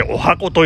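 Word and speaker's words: 十八番: 当時、私のカラオケ 18 番 18 番と書いてお箱と読む、えー、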